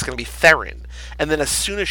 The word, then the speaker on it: Theron: In South African, it's like Thrun. Like, it's like this really sort of guttural one-syllable word or sound.